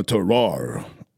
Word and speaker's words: terroir: You have to think French when you're trying to pronounce that. – Yeah. A terroir.